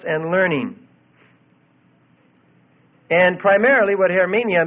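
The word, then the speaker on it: hermeneia (ἑρμηνεία): The Greek for interpretation is hermeneia, H-E-R-M, long A, N-I-A, hermeneia.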